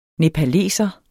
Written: nepaleser

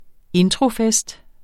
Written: introfest

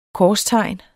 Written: korstegn

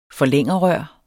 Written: forlængerrør

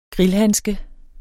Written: grillhandske

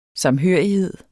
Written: samhørighed

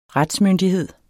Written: retsmyndighed

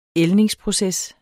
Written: ældningsproces

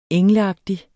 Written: engleagtig

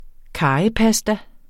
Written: karrypasta

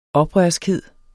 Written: oprørskhed

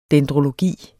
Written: dendrologi

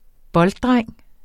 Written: bolddreng

